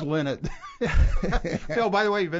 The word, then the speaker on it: Lynott: Lynott. – I lie not. No, he told me it's Lynott.